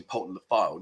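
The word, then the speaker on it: Poulton-le-Fylde: And for those of you that had a go at me the other week, because I said my mum lives in Poulton-le-Field. And she doesn't. She lives in Poulton-le-Field. But people are sending me messages saying, you said that wrong. Your mother lives in Poulton the Fylde, not Field.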